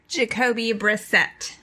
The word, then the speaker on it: Brissett: Brissett, is that how you say his name? Brisket? – Jacoby Brissett. – Jacoby Brisket.